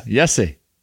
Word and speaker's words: Jesse: It's a soft J. – Yeah. Yes. Yesse. – Yesse. Of course.